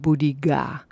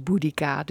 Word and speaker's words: Boudica: dat het uitgesproken werd als Boudiga. Dus met een soort G. Maar goed, algemeen gangbaar is Boudica, dus dat eh, doen wij dan ook.